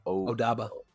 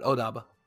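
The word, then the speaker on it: Obada: Odaba. Yeah, you said it right. Odaba.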